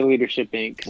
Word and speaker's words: Leadership Inc.: Or is it Leadership Inc. or Leadership Incorporated? How would you say that, Ty? – I would say Leadership Inc.